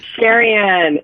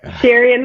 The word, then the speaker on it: Sherian: Sherian. It's like Liberian. – Okay. Sherian, yeah. Okay. – Yeah, Sherian the Liberian, yeah?